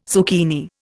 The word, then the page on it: zucchini: Indian